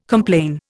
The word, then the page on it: complain: Indian